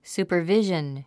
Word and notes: supervision: sùːpə r víʒən; スーパァビジョン

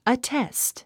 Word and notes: attest: ətést; アッテェスト

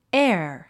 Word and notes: heir: éər; エア

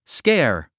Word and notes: scare: skεə́ r; スケェア

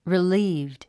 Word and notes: relieved: rilíːvd; リリーブド

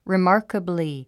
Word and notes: remarkably: rimάː r kəbli; リィマーカブリィ

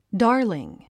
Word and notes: darling: dάː r liŋ; ダーリン

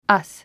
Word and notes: us: ʌ’s; アス

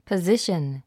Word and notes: position: pəzíʃən; パズィシャン

